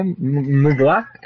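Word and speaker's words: Mgła: Recently, I've been into Dark Throne, Mayhem, Mgla. – Yeah, Mayhem. – If I pronounce it correctly. And I also got Mgla from Russia. Yeah, Mgla, M-G-L-A, Mgla, Polish.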